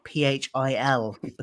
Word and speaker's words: Phil: And then you can find me on Twitter as well, which is @CultureFilter, and filter is spelled PHIL because my name is Phil.